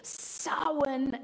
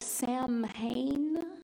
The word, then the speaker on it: Samhain: Samhain and All Hallows. It looks like Sam Hain. But apparently, because the Irish alphabet has nothing to do with the English alphabet, it's pronounced Samhain. Samhain, Samhain.